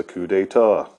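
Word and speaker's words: coup d'état: First thing I want to talk about is a coup d'etat.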